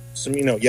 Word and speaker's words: Cimino: But for me, the standout performance in this is, and hopefully I'm pronouncing this man's name correctly, Leonardo Cimino. – Cimino, yeah.